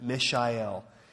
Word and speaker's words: Michelle: It's spelled Michelle, but she pronounces it Mishael.